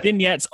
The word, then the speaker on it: Vignettes: It is called, I'm going to try to pronounce this, Vignettes of a Pestilence. – Exactly. Yes. – Oh, fantastic. See, I wasn't sure whether to put any accent on it, like vignette or anything like that. But yeah. Vignettes of a Pestilence.